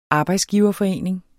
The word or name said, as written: arbejdsgiverforening